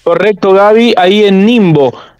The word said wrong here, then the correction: Ningbo: o, Nimbo Ningbo sería, eh, eh, según la, la, pronunciación en chino,